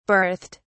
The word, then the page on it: berthed: British English